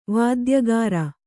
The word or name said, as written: ವೈದ್ಯಕಾರ